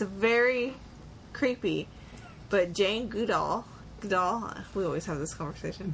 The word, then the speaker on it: Goodall: Say good. – Good. – All. – All. – Combine them. – Goodall. – Good doll. You're saying it like Gal Gadot.